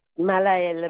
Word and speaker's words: Malahel: Malaël Madli. – Ça s'écrit comment? – M-A-L-A-H-E-L. – Oui. – Madli, M-A-D-D-L-Y.